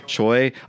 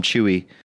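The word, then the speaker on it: Chuy: It's spelled Choi. Choi. I was going to call him Chewy.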